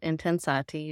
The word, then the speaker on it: Intensati: And I'm glad you were able to take a look at Intensati. You did say it right.